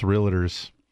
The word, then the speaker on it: realtor: And I go, oh, man, it's realtor. – I just want to. – There's no I in it. You're putting a whole nother syllable in this word.